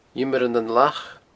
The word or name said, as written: Iomair nan Lach